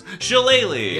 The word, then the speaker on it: Shillelagh: Because Shillelagh.